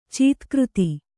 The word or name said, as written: ಚೀತ್ಕøತಿ